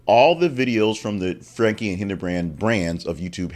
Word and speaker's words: Franke: It is Franke. The E is pronounced.